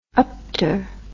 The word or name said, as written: upter